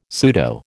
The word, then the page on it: pseudo: American English